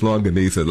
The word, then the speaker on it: longaniza: We're in Brooklyn, where brisket and lasagna. Am I saying that right? Help me, man. – Longaniza, Freddie. Oh, see, I got it all messed up.